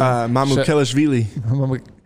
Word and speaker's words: Mamukelashvili: Mamukelashvili.